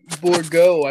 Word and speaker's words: Bourget: Trenton Bourget, I believe is how you say his name. It's like French or something.